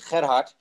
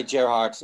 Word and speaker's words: Gerard: If you pronounce it on a Dutch way, it's Gerhard, but you can say Gerhard. No problem.